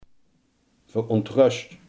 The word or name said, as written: verontrust